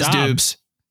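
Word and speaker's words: Doubs: How do you say his name? Is it Dubes? I thought it was Dubes. – It is Dubes.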